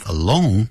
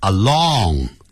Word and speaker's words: alone: Studies show, 诸多的研究啊显示出啊 that people who live alone, 记住不要念成 along, 那是 A-L-O-N-G, along 是沿着 he's walking along the street, 他沿着街道走。